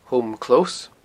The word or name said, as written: Home Close